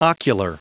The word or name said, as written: ocular